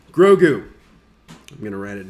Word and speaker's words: Grogu: So anyway, that's Goku's backstory. – Grogu. – Grogu. I cannot get the name right.